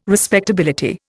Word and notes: respectability: Indian

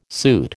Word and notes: suit: American English

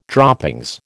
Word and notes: droppings: American English

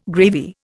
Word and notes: gravy: Indian

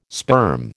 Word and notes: sperm: American English

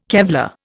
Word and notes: Kevlar: Indian

